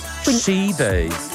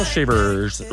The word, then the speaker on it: Shivers: Allora, essendo lui inglese, quindi britannico, Ed Sheeran con Shivers. Shivers. – Shivers. – Shivers. Se fosse americano, Shivers. – Cioè la R americana in questo caso.